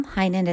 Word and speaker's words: Heynen: Heinen is spelled H-E-Y-N-E-N. And from there, you can get a lot of information on how I work with my clients one-on-one.